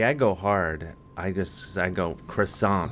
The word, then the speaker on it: croissant: I don't like to say croissant, and take the T off, or. – Only pronounce the T if there's an E at the end of it.